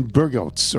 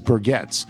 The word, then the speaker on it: Burgets: Some time ago, I heard you introduce a song from Norwegian Soft Kitten and mention one band member named Glenn Burgots, or Burgets, it's pronounced. He says Burgets.